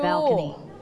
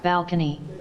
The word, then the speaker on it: balcony: Balcony. – Okay, one more time. – Balcony. – Balcony. – Balcony. Don't forget to pronounce le sound, bal. You have to roll your tongue.